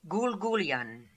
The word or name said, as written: Goolgoolian